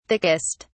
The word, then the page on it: thickest: British English